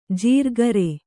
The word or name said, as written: ಜಿರ್ಕರೆ